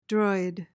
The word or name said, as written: droid